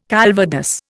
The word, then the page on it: calvados: Indian